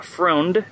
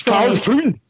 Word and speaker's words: Freund: And this film is the first one that we're going to talk about tonight, directed by Carl Freund. Am I pronouncing it correctly? – That's Carl Freund. – Actually, I speak German fluently, and it is Freund.